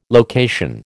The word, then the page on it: location: American English